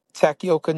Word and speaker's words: Takioka: How you pronounce it? – Takioka.